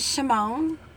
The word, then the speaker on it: Shamone: My name is Shamone LaMarra. Yeah, I was named after Michael Jackson. I thought you wasn't with your intro. – Pronounce it again. – What? My name is Shamone.